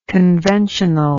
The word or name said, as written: conventionally